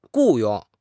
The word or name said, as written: 雇佣